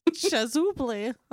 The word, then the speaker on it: chasuble: I think it's pronounced Chasuble? Chasuble? Chasable. – Chasuble.